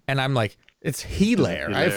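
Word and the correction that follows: Helaire: I'm like, yeah, oof, Hilaire. Jesus. I'm like, where is the guy? I can't find him in the stats. – Eclair.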